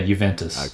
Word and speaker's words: Juventus: How do you say it? Juventus? – Yeah, Juventus.